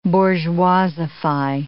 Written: bourgeoisify

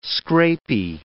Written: scrapie

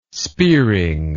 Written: spearing

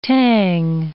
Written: tang

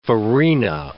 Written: farina

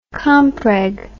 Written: compreg

